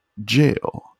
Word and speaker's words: gaol: And it's like, jail. I'm like, what? Oh. I was like, are you fucking kidding me? I was like, is this jail? – Yeah.